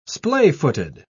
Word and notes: splayfooted: British English

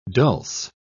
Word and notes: dulse: British English

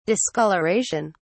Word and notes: discolouration: British English

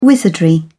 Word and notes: wizardry: British English

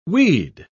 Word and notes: we'd: British English

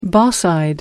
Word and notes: boss eyed: American English

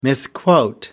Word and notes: misquote: American English

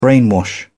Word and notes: brainwash: British English

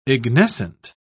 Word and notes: ignescent: British English

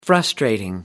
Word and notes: frustrating: American English

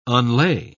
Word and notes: unlay: British English

